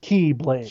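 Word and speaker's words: Keyblade (χ-blade): It's actually spelled X-blade, but yeah, it's pronounced Keyblade.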